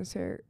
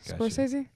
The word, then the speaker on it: Scorsese: Uh, Shutter Island was done by Martin Ser- Ser- Ser- Scorsese.